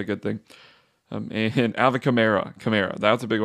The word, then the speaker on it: Kamara: He did say it kind of depends on how it rolls off the tongue, so some people say Kamara, some people say Kamara. He didn't seem to mind that much, but Kamara is the way he pronounces it, so that's the way that I will pronounce it.